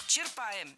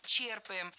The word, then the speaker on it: черпаем: Черпаем, неверно. Правильно, черпаем. Здесь ударение тоже должно падать на корень слова. Черпать, черпаешь, черпает, черпаем.